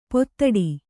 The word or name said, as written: ಪೂದೋಟ